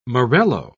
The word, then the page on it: morello: British English